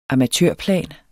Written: amatørplan

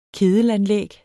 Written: kedelanlæg

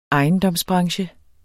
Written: ejendomsbranche

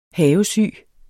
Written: havesyge

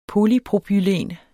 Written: polypropylen

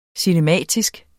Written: cinematisk

